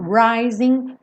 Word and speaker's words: rising: Rising frequentemente, 99,99% das vezes, um G numa palavra com ING não é pronunciada. São pouquíssimos sotaques no mundo que pronunciam esse G. Então, rising, rising of the sun.